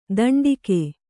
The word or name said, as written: ದಂಡಿಕೆ